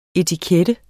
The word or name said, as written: etiket